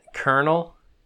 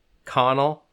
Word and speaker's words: colonel: It's like, what's it, Colonel? Connell? It's spelled Connell, the way it looks, but it's Colonel.